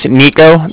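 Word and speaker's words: Niko: So, my name's Nico. That's N-I-K-O.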